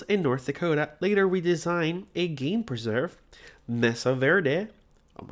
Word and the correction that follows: Mesa Verde: my god, I'm Spanish and I said that wrong. Mesa Verde, there we go.